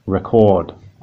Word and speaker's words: record: And you'll notice that the first syllable is stressed in record. So we say record, record. But in that second word, so record, as in record an interview, you'll notice that we stress a different part of the word.